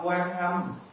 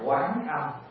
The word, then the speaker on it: Quán Âm: Cho nên chúng ta không đọc quan âm, chúng ta phải đọc là quán âm.